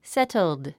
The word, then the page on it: settled: sétld; セェットゥド